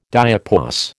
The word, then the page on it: diapause: American English